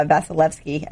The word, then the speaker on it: Vasilevskiy: Vasilevskiy. I can never pronounce it; probably butchered it. – No, you nailed it